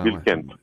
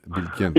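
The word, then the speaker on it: Bilkent: Είναι, στην ουσία είναι σύνθετη η λέξη, οπότε υπάρχει ένας μικρός τόνος και στις δύο λέξεις. Είναι Μπίλκεντ. – Μπίλκεντ.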